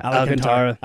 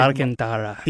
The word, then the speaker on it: Alcantara: How do you say his last name? – Alcantara. – Alcantara. Yeah. I just think Alcantara.